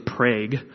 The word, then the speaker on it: Prague: It's spelled Prague, like the country, or is it a country or a city? I don't even know. It's spelled like Prague, but we're Oklahoma, and we can't say anything fancy, so it's Prague.